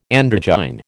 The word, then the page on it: androgyne: American English